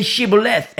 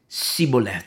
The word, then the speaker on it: shibboleth: You know, say shibboleth, and then the Ephraimite says sibboleth. They just couldn't speak that way. He couldn't pronounce it right.